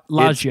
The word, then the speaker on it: Ladja: L-A-D-J-A. Ladja. – Yeah. – Oh, Ladja. – No, it's Ladja. – Ladja. – It's pronounced Ladja. But it is sure? spelled with a J.